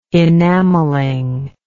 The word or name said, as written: enameling